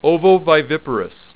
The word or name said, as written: ovoviviparous